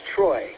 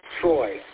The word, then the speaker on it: Troy: The name here is Troy. Spelled phonetically, Tango, Romeo, Oscar, Yankee. Troy.